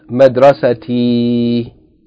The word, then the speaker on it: Madrasati: It is entitled Madrasati. And I'm emphasizing the E sound at the end so that we can recognize that this is the first person singular pronoun known as Ya Al-Mutakallim.